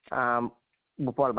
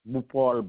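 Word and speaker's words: Bhupaul: Bupal Baiju. – I'm sorry? – Bupal Baiju.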